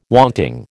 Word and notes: wanting: American English

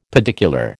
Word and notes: pedicular: American English

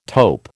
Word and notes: taupe: American English

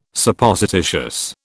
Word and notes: Supposititious: British English

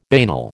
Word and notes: banal: American English

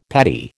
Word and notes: patty: American English

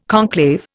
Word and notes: conclave: Indian

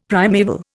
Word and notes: primaeval: Indian

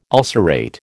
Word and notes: ulcerate: American English

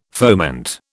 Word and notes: foment: British English

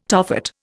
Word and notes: Tophet: Indian

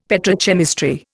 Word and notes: petrochemistry: Indian

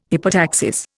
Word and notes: hypotaxis: Indian

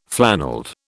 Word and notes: flannelled: British English

